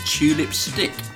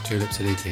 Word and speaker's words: Tulip Siddiq: She is the aunt of Tulip Stick. I might have said her name wrong. Who's a Labour MP for Hampstead and Kilburn. – Tulip Siddiqui.